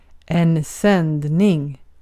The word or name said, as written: sändning